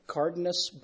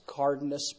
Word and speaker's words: Cardenas: It's spelled C-A-R-D-E-N-A-S. So that's like Cardinus basalt is what the layer was, Cardinus basalt. And within that Cardinus basalt, they tested those three different isotopes that they found.